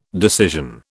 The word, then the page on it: decision: British English